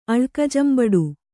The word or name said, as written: ಅಳ್ಕಜಂಬಡು